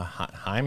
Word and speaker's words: Heim: Okay, so the pitcher's going to deliver, and the significant part is the catcher for the Rangers is Jonah Heim. Is that how we pronounce his name? – And it's a 2-2 count. I believe that is.